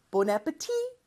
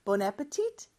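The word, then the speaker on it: Bon appétit: 그래서 원래 프랑스어처럼 하려면 마지막 T를 발음하면 안 돼요. Bon appetit! 그런데 미국에서는 주로 T를 발음해서 Bon appetit! 이렇게 하는 경우가 있다고 했었죠.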